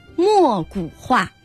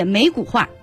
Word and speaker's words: 没骨画: 节目的下半时段我们依然给大家准备了一道题目啊，在中国的传统书画中有一种画作叫墨骨画，这个墨就是没有的没，它是多音字啊，就是简单的理解没骨画，墨骨画。